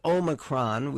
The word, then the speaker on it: Omicron: But it is Omicron.